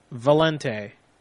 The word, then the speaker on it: Valente: Okay, my name is Valente. – Who? – Valente. – How do you spell that? – V as in Victor, A-L-E-N-T-E.